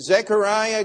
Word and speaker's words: Zechariah: And so all of these things were going to happen, and Zechariah goes out.